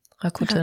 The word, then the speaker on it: Rakuten: Rakuten.